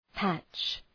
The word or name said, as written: patch